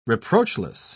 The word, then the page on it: reproachless: British English